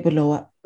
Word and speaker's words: Bulawayo: My parents are Shona and they say Bulawayo. Akaenda ku Bulawayo. We say that B like that. I think it's because are you Ndebele or are you Shona? But also you're from there, so you pronounce it properly. That's the proper way to pronounce it.